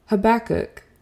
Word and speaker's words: Habakkuk: Habakkuk. Okay, Habakkuk 2:2-4.